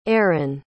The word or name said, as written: Eron